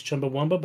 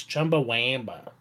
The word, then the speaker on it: Chumbawamba: Everyone thinks it's Chumbawamba. Eric thought it was Chumbawamba, but it's not. It's Chumbawamba. – Wow, Chumbawamba. Yeah, like that. – What'd you say? – Yeah, you do have to pronounce it like that.